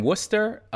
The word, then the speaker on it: Worcester: I was born in Worcester, and then I was raised for a while in Boston. – I still can't pronounce that word, Worcester. – Worcestershire sauce. That's how you say it.